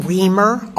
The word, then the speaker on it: Riemer: My name is Dr. Ellen Reamer, R-I-E-M-E-R.